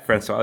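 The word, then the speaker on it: François: And so can you just tell us a little bit more about a Haitian-American father, Leonard, am I saying it right? Francois. – Yeah, Francois, that's right.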